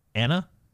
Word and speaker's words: Iana: I'm going to call her Anna with a silent I at the beginning. Oh. So I... – At the beginning? – Yeah. – Wait, how do you spell it? – I-A-N-A. – Iana.